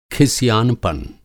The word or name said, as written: खिसिआनपन